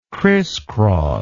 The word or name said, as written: crisscross